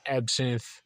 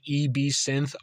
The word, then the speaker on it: Ebsynth: The first platform I want to get into and kind of dive deeper and talk about is Ebsynth. It's spelled E-B-Synth, all one word. I used to call it E-B-Synth because I didn't know the pronunciation was Eb, but I was vehemently corrected online on Twitter and all that good stuff.